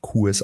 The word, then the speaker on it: Kues: Mein Name wird tatsächlich, so wie du ihn richtig ausgesprochen hast, Kues ausgesprochen.